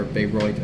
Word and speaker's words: Bayreuth: Or Bayreuth, as apparently it's pronounced.